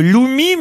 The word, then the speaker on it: loomi: Ça vient d'Iran, ça s'appelle le Loumi, mais de quoi s'agit-il? – Un missile balistique? – Non. Ça s'écrit L-O-O-M-I.